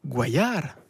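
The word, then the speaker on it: Guallar: Et un troisième article sur qui est Gabriel Guayar, parce que ça se prononce comme ça, parce qu'il est d'origine espagnole, la discrète compagne de Benoît Hamon.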